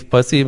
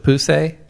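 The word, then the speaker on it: Poussey: Like, her name's Pussy, but you pronounce it Puse.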